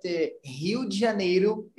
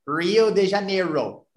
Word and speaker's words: Rio de Janeiro: And we always say that Rio de Janeiro, it's going to be sounds like Rio de Janeiro. – Rio de Janeiro. – Yeah, but if we change the R from R to H, is it going to be perfect pronunciation? – Oh, it's easy.